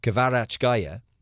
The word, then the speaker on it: Kvaratskhelia: Kvaratskhelia, nice.